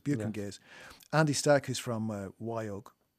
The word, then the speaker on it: Buke and Gase: And the guests are the Metropolis Ensemble, Aron Dyer, from, you're going to help me with this, because I play Buke and Gase on the show, but I'm never 100% sure I'm pronouncing the name correctly.